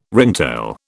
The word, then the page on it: ringtail: British English